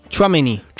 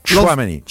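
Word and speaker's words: Tchouaméni: Ciuameni. Per... Ciuameni? Ciuameni. – Ciuameni.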